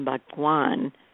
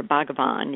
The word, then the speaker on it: Bhagavan: So now the chambers actually are known as the Sri Amma Bhagwan, or Bhagavan, you could pronounce it either way, sacred chambers, Sri Amma Bhagavan sacred chambers.